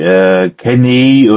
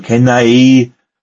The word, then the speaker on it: Khamenei: Ich glaube, das wird wie Kenei oder Kenai, also Khamenei, ausgesprochen. Ja.